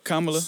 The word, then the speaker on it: Kamala: I'm going to pick Kamala Harris. – So. – Is that how you say it? – Yeah. – Kamala.